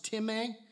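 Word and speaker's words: time (τιμή): It's the word time. That's how it's pronounced, time.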